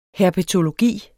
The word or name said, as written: herpetologi